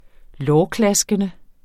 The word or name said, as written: lårklaskende